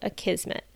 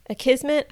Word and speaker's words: Akismet: And the next blogging related automation is to take care of spam comments with a plugin called Akismet. Akismet, I might be pronouncing this wrong, it's A K I S M E T, is a low cost plugin.